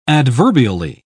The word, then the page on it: adverbially: British English